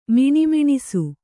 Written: ಮಣಮಣಿಸು

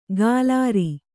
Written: ಕಾಲಾರಿ

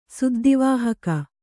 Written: ಸುದ್ದಿವಾಹಕ

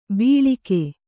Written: ಬೀಳಿಕೆ

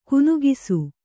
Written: ಕನಿಕಿಸು

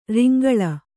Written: ರಂಗೋಲಿ

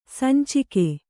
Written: ಸಂಚಿಗೆ

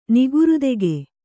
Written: ನಿಗುರುತೆಗೆ

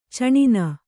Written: ಛಿನ್ನ

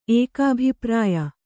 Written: ಏಕಾಭಿಪ್ರಾಯ

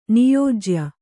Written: ನಿಯೋಜ್ಯ

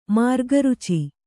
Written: ಮಾರ್ಗರುಚಿ

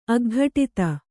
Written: ಅಗ್ಘಟಿತ

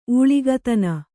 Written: ಊಳಿಗತನ

